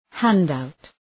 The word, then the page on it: handout: ‘hænd,aʋt